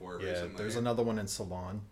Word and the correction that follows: Solon: Am I pronouncing that right? Mm. Uh, Solon.